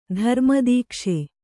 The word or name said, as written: ಧರ್ಮದೀಕ್ಷೆ